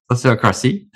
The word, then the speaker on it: Sociocracy: including implementation and training of the community in Sociocracy. I said that correctly, right? – Sociocracy.